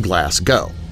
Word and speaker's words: Glasgow: Firstly, Glasgow, where I work, is pronounced Glasgow.